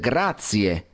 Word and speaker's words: grazie: Otra cosa, grazie, non si dice grazie, simplemente le tienes que poner la E. Al final, entonces, grazie, con la E final.